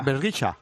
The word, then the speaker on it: Bergischer: Bueno, ¿se dice Bergischer o cómo se dice? – Bergischa. – Bergischa, con A al final, porque las palabras que terminan en ER al final se pronuncia como una A.